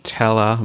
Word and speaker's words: tella: I don't know if people are drinking that here, like Tej and Tella. Am I saying that right? – Yeah. So Tej is honey wine.